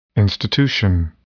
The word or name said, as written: institution